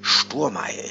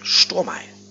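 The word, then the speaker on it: Szturmaj: Benjamin Sturmey. Jetzt hoffe ich wirklich, dass ich den Namen richtig ausgesprochen habe, denn das wird SZT. Urmaj geschrieben. Sturmei.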